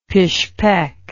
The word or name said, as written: Pishpek